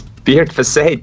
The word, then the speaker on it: Beardface: Okay, can you tell me, in the hospital, Dr. Beardface, as they all call him, insists that his name is pronounced exactly how? – Beardface. – Not close enough. – Do you want to correct him on this? – It's Beard Fassay, damn it.